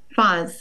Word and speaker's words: Fahs: Am I pronouncing your last name right? Foss? – Fahs. – Oh, yeah. Fahs.